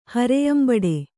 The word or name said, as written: ಹರೆಯಂಬಡೆ